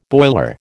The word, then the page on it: boiler: American English